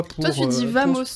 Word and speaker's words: vamos: Bamos. Eh Bamos. Le V se prononce B.